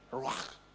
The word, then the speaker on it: ruach: The Hebrew call it ruach. Have I said it right? That one. Yeah, thanks, Udev.